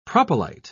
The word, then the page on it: propylite: British English